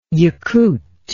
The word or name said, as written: Yakut